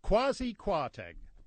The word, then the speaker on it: Kwasi Kwarteng: I mean, he's got a perfectly respectable name, Kwasi Kwarteng. – What is it? – Kwasi Kwarteng.